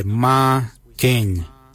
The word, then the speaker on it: marrgeny: The number 6 we say ma ken. Ma ken is spelled M-A-R-R-G-E-N-Y.